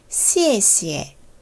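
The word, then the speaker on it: 시에시에 (谢谢): 시에 시에 시에 시에 X, I, E로 병음 표기를 합니다. 네 가지 성조 중에 마지막 꼭대기에서 포물선을 그리며 번지점프를 하듯 음을 떨어뜨리는 사성이에요. 시에시에. 사성을 두 번 연달아 할 때는 첫 번째만 정확히 사성음으로 발음해 준 후, 그 다음에는 가볍게 경성으로 처리돼요.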